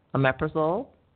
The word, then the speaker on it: omeprazole: And looked at four of the most popular proton pump inhibitors, omeprazole, pantoprazole, lansoprazole, and esomeprazole.